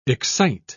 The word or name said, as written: excite